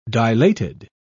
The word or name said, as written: dilated